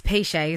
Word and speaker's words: Piché: I wasn't going to dare to say your last name because it's got an accent on the end, but it's Piché, isn't it? – It is, yes.